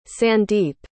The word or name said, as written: sandeep